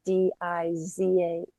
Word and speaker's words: Dizhong: Oh, by the way, this is between a Zen master, Dizhong, Sounds like Dijon, the mustard, but it's Dizahng, transliterated, but Dijon.